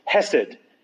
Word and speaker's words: hesed: The Hebrew word is hesed, H-E-S-E-D or C-H-E-S-E-D. So hesed or hesed, properly pronounced.